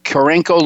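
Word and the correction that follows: Carencro: Louisiana at Old Evangeline Downs. What was the culture shock going from Cincinnati down there? – It was a completely different world. It's Karen Crow.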